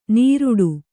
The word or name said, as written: ನೀರುಡು